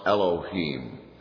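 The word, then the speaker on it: Elohim (אֱלֹהִים): One generic Hebrew word for God is pronounced Elohim.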